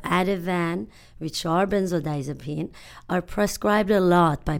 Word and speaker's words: benzodiazepines: I heard that benzodiazepine, did I say that right? Benzodiazepine? – Benzodiazepines.